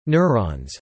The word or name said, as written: neurons